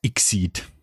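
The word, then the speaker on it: XSEED: Einmal mehr hat, wie spricht man das aus? Exceed, Exceed? – Ich nenne es immer Exceed.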